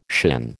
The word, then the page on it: shin: American English